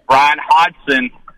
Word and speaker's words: Bryan Hodgson: And I listened to his interview on the Buzz this morning out of Little Rock, and they actually asked him, you know, how do you pronounce your name? And he said, Bryan Hodgson. And so I think everybody was kind of like, oh, wow, we've been mispronouncing his name this whole time.